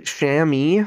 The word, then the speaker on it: chamois: I'm happy I looked up how to say that, because it's spelled chamois, and I had about 4 different ways of saying it. – Chamois. – And chamois was none of them.